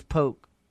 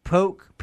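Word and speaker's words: Pook: My name is Poke. Nice to meet you. – I'm sorry. How do you spell that? – Poke, P-double-O-K.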